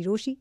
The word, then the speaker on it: Rochie: मेरा नाम है मिर्ची रोशी, नाम की स्पेलिंग है ROCHIE, so hit me up, do whatever you have to do, लेकिन कहीं मत जाना क्योंकि वापस तो यही है आना।